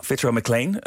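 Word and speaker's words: Fitzroy Maclean: Ja, Fitzroy McLean.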